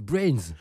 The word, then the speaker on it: BRNS: On prononce Brains.